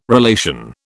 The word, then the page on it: relation: British English